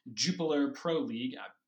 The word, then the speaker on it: Jupiler Pro League: Another headline coming out of Belgium is that Club Brugge are likely to be crowned the league winner of the Jupiler Pro League.